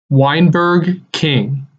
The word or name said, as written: Weinberg King